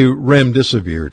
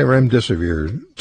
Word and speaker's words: remdesivir: If I say remdesivir to you, I don't even know if I'm saying it correctly. – Yeah, you are. – Am I? – Yeah. – Okay, so if I say remdesivir to you, and I can't remember how to say the other one, hydroxychloroquine? – Yeah, hydroxychloroquine.